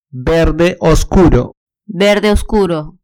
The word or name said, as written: verde oscuro